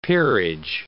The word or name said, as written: peerage